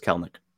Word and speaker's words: Kelenic: Again, I'm going to point it out, Kelnick, because I always used to say Kelenic or Kelenic, because I said Kelenic, and then somebody corrected me to Kelenic, and it turns out the E is silent in the middle. It's just Kelnick.